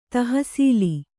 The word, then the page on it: ತಹಸೀಲು: tahasīlu